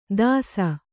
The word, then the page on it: ದಾಸ: dāsa